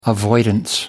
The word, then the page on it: avoidance: American English